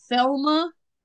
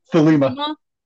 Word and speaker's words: Thelema: And Ende possessed items relating to the Thelma. I think I'm saying that right. – Thelema.